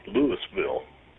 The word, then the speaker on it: Louisville: It's actually pronounced here Louisville. I guess it's just a southern thing. You know, they can't spell it different, so they got to pronounce it different.